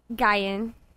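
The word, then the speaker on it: Nguyen: So how's it said? – Guyan. – Oh. Really? – The N is silent.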